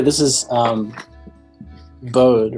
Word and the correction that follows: Bode: Oh yeah, it's actually pronounced Bodie, but I didn't know that. I was pronouncing it Bode until I like started working there.